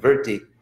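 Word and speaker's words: vṛtti: And it's actually pronounced technically in Sanskrit, vritti.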